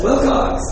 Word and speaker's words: Wilcox: That's Wilcox. W-I-L-C-O-X.